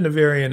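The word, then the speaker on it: Nevèrÿon: So accent on the sentence. – Navarian. – Navarian on the second syllable.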